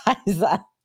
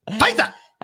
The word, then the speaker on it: Pfizer: You didn't say it right. You still didn't say it right. – What, Pfizer? – He might not be able to go work for Pfizer. – Pfizer. – You've got to say it right. Pfizer.